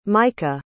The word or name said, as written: micha